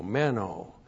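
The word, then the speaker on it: meno (μένω): M-E-N long O, meno.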